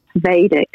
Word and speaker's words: Vedic: And what I loved about Vedic, and is it Vedic or Vedic, by the way? – Vedic. Okay. Vedic. – Good, I said it correctly in the first place.